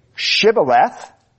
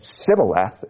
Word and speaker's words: Shibboleth: such that ones who could say Shibboleth didn't die, and 40,000 who could not pronounce it correctly but said Sibboleth instead of Shibboleth lost their lives.